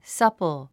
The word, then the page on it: supple: sʌ́pl; サッポォ